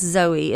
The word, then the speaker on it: zoe: But then there's one that's Zoe. It's spelled like Z-O-E.